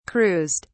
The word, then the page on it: cruised: British English